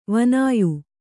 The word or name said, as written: ವಾನೇಯ